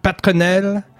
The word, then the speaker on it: Peternell: How do you say it? Peternell. Yeah, very American. I was ready to French it up. Peternell. – No, that sounds good too.